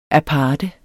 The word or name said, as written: aparte